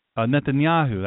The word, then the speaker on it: Netanyahu: It isn't as Benjamin, I had the pleasure of being a guest on a show with him, and Benjamin Netanyahu, that's how you pronounce his name correctly,